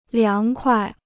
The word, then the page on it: 凉快: liáng kuai